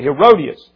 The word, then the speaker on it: Herodias: Herodias is how you pronounce it. Okay, Herodias.